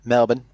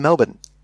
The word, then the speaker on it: Melbourne: Melbourne. Not Melbourne, as it's spelled.